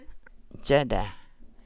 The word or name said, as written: jaadaa